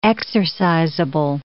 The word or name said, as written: exercisable